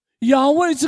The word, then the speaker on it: 亚威: 这个 I am 在出埃及记，在希伯来语的发音的里面，亚威这个音，我们现在把它翻译成中文的发音，就翻译成耶和华。